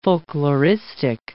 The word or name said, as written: folkloristic